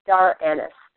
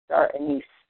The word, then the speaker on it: star anise: The third ingredient we're going to talk about in influence is called star anise, or star anise, depending how you say it.